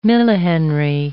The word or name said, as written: millihenry